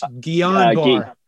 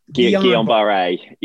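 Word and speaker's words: Guillain-Barré: what they discovered was this increased, the vaccine that they used increased exposure to, I always call this Guillain-Barre, and that's not the pronounce, it's French, Guillain-Barre. That's Guillain-Barre, yeah.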